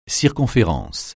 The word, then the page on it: circonférence: français